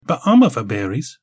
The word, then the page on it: bamava-berries: Galindri